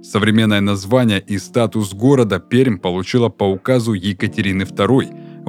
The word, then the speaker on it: Пермь: Город, находящийся на берегу Камы, город в Придуралии площадью 800 квадратных километрах и четвертый по масштабу населенный пункт нашей страны – город Пермь. Название города произносится с мягкой «р», хотя это и противоречит правилам русского языка. Благодаря этой особенности местные жители легко отличают приезжих.